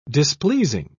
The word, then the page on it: displeasing: British English